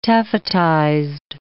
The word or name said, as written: taffetized